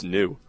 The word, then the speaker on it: Ng: Jeff says Ng is pronounced new. – New? – Yeah. – Okay. That's a new one. – N-E-W.